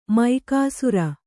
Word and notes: ಮೇಗುಸಿರ್: Kannada

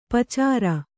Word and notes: ಪಚ್ಚೇರಿ: Kannada

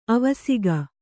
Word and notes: ಅವಸೇಕ: avasēka

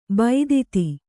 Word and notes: ಭೇದಿತ: bhēdita

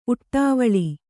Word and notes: ಉಡುವಲಿ: kannada; uḍuvali

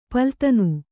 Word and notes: ಪಲ್ಲುದಿನು: palludinu

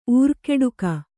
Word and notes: ಉರ್ಕುಡುಗು: urkuḍugu

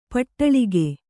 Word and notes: ಪಡಲಗೆ: Kannada

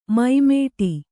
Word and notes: ಮೈಮೇಟಿ: Kannada; mai mēṭi

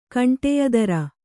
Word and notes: ಕಂಟಿಯದಾರ: kaṇṭiyadāra; Kannada

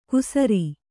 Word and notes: ಕುಸರಿ: kusari

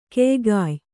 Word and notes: ಕೆಯ್ಗಾಯ್: keygāy; Kannada

